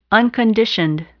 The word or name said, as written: unconditioned